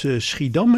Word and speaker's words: Schiedam: Jij bent uit Schiedam, hè? Zeg ik het goed? – Ja, klopt. Ja.